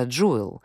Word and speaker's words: Juul: Даже само ее название произносится Juul и на английском звучит как слово «драгоценность», подчеркивает уникальность и привлекательность продукта, особенно для молодежи.